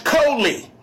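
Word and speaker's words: koli (חֳלִי): It's pronounced koli. It's spelled C-H-O-L-I-Y.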